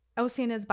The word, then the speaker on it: Oceanids: Oceanids, by the way, you spell it kind of like the ocean with IDS at the end, but the pronunciation is a little bit weird.